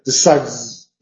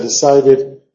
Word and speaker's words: decided: Si el último sonido en el infinitivo es D o T, tienes que pronunciarlo como una sílaba. No puedes decir decides, ¿ya? Tiene que ser decided, tiene que ser hated.